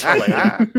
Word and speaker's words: Helena: Guys, that's how you say the name. Listen up. It's Helena. Yeah.